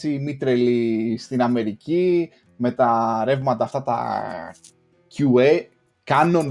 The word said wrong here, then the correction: QAnon: δεν ξέρω πώς να το προφέρω και σωστά. – Ναι, QAnon.